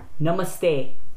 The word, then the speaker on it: namaste: It's a short uh. It's almost like if you can imagine it being written N-U-M-U-S-T-A-Y. – And also the namaste. Syllable is on ma. – Right. It's not namaste. It's not namaste. – It's namaste. – Namaste. So you kind of emphasize the ma.